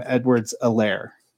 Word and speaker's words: Edwards-Helaire: I have not heard anybody else pronounce it without, you know, they were basically calling him Edwards-Helaire.